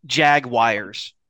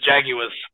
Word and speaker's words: Jaguars: It's not Jaguars. The correct pronunciation is Jaguars. – We won in 1776 so that we don't have to follow those fucking queen-worshipping freaks' way of pronouncing words. – There is no world where that spelling is pronounced Jaguar.